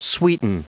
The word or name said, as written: sweeten